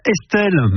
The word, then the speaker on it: Estl: Elle s'appelle Estelle, mais ça s'écrit comme si vous écriviez un SMS. Estelle, c'est son vrai prénom, mais elle l'écrit E-S-T-L, et elle est belge.